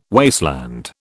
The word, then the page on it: wasteland: British English